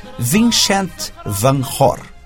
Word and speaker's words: Vincent van Gogh: São inúmeros, mas vamos mencionar apenas três: Hieronymus Bosch, Rembrandt e, no século XIX, o fabuloso Van Gogh, cujo nome se pronuncia na língua holandesa Vincent van Gogh.